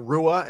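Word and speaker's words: Ruah: Daniela Rush or Rua, excuse me, is 39 years old.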